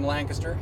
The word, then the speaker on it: Lancaster: He's from Lancaster, and you know that I'm legit because I'm saying it correctly. Lancaster basically has barely three syllables. It's kind of two.